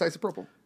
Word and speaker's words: isopropyl: How do you pronounce isopropyl? Is it right? Is that right? – Yeah, it's isopropyl.